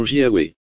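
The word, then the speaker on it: Jahwe: Vous vous souvenez peut-être qu'en allemand J et Y, en allemand J se prononce Y, d'où nous obtenons Jahweh.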